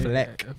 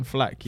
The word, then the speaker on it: Flack: Fleck. – Fleck. – Is that the original pronunciation? – I don't know. – Caroline Flack, yeah. – Flack. Caroline Flack.